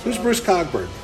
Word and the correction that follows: Cockburn: Isn't he a musician? – It's Coburn. – Coburn. – It's pronounced Coburn.